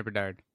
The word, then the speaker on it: Bedard: Did I say his name right? – Yeah, Connor Bedard.